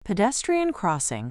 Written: pedestrian crossing

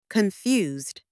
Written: confused

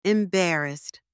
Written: embarrassed

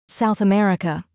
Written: South America

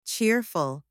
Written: cheerful

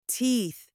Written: teeth

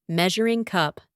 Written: measuring cup

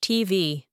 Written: TV

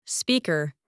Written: speaker

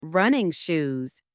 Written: running shoes

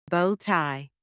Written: bow-tie

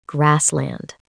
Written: grassland

